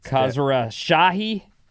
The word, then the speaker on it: Khosrowshahi: Uber has chosen its new leader, Expedia CEO Dara Khosrowshahi. – Yeah. – Khosrowshahi? – It's close enough.